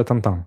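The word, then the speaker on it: Tintin: Ну да, на французском она произносится там-там. Да, там-там. И тут Тинтин говорят. А на русском? – Я вообще не знаю.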